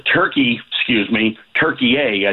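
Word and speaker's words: Türkiye: when Turkey A, as it's pronounced now, gets into the group with the United States.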